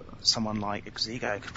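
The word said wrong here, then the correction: Xsigo: Oh, it's Sego. It's Sego. It's not Exego. The X is silent.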